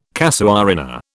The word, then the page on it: casuarina: British English